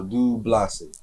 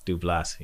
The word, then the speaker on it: DuBlase: And that was my first question because I was like, all right, how you say it? I want to make sure I say it correctly. – Right. – So DuBlase. – DuBlase. Yep.